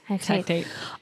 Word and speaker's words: Hecate: Yeah, it's Hecate.